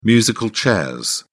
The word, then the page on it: musical chairs: British English